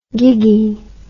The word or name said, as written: giggy